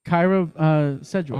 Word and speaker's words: Kyra Sedgwick: Kyra Sedgwick.